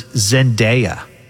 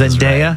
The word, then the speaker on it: Zendaya: So they asked these people in this YouGov poll, and the number one celebrity was Zendaya. – Really? – Did I pronounce that correctly? – Yes, that Zendaya.